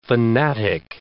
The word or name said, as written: fanatic